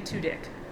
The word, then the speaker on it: Tudyk: You keep saying Tudyk. – That's how you pronounce his last name.